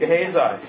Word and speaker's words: Gehazi: How do you say his name? Someone help me with his name. – Gehazi, yes.